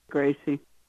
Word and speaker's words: Gracie: Gracie. – Oh, Gracie. I'm so sorry. And is that G-R-A-C-I-E? – Yes.